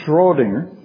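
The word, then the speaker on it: Schrödinger: Schrodinger was at, I got to say it right, Schrodinger. You need more liquid to do that, because you spit most of it out when you're able to say his name correctly. Schrodinger was attacking indeterminacy.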